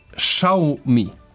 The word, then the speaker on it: Xiaomi: sad svi različito izgovaraju. Pravilan izgovor je Xiaomi. – Xiaomi.